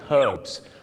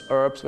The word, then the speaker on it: herbs: ชาวอังกฤษก็จะออกเสียง herbs ตัว h หอนุฮูกชัดเจนมากแต่ชาวอเมริกาเยอะมากครับออกเสียงว่า herbs ไม่มีตัวหอนุฮูกกลายเป็นออ่าง